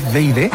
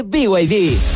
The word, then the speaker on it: BYD: ¿No es VID? – Nada que ver, se escribe BYD, pero se dice BYD, la marca número uno de electromovilidad en Chile y el mundo.